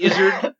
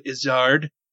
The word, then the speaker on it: Izzard: Went to Eddie Izzard or mm-hmm. Eddie Izzard. Sorry, yeah. he mm. pronounces it Izzard.